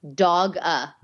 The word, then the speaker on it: Doga: D-O-G-A. Doga. – Doga. – Yep. – Never. Is it like yoga only with a D? – Yep.